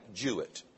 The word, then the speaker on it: Jouett: And the hero of this story is one man by the name of Jack Jewett. Now, his last name is spelled J-O-U-E-T-T, but it's pronounced Jewett, like through, the ooh and through, so it's Jack Jewett.